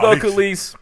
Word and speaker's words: Belcalis: Belsalis, I don't know. Belsalis Belcalis.